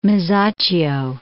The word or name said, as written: Masaccio